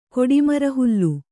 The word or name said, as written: ಕೊಡಿಮರಹುಲ್ಲು